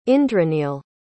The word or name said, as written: indraneel